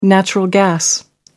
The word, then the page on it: natural gas: American English